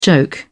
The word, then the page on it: joke: British English